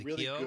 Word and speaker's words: Keough: Really Keough.